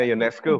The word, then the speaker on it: Ionescu: Sabrina Ionescu.